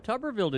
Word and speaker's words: Tuberville: Tuberville didn't graduate from Texas Tech, did he? – Hey, I'm just glad Coach Blair knows that it's three syllables.